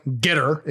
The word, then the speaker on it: Getir: And so I was pretty excited to see news that Getter, or Getter, if you're pronouncing it with an American accent, bought Fresh Direct for an undisclosed amount relatively recently.